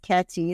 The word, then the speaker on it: Katty: There's no D in my first name. It's Katty. It's like Kathy without the H, and it's Gashazvi.